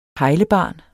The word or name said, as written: pejlebarn